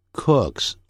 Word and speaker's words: cooks: 很会煮菜 My wife cooks well. 那个 cooks 的发音跟 book good 发音是完全一样，不要念成 cook，要念成 cook，再加个 s 念成 cooks.